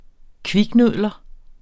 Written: kviknudler

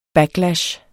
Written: backlash

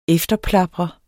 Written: efterplaprer

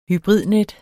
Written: HYBRIDNET

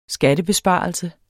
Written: skattebesparelse

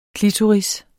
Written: klitoris

